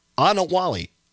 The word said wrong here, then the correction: Anawanali: Anawanali, there it is, Anawanali, I believe that's the correct pronunciation, was ineligible for track.